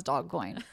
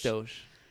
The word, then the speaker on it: Doge: Is that how you pronounce it? – Yes. It I thought it was Dogcoin. – I thought it was doggy. – It's Doge.